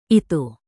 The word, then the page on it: itu: indonesian